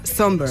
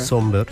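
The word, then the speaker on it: Sombr: Pues mira, yo busqué cómo se pronunciaba por si acaso, pero efectivamente se pronuncia Somber. – Somber, sí.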